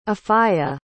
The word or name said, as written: afya